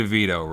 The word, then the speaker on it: DeVito: I was thinking today, you know, I was thinking of Danny DeVito, right? And rhymes with Dorito, right?